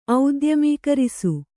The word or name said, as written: ಔದ್ಯಮೀಕರಿಸು